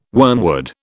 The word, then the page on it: wormwood: British English